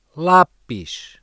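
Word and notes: lápis: European Portuguese

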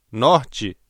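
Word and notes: norte: Brazilian Portuguese

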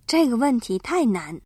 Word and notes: 问题: wèntí

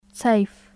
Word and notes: أين: /ʔayna/

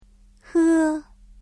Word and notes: 喝: hē

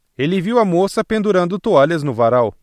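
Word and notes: pendurar: Brazilian Portuguese

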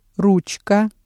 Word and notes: ручка: Russian

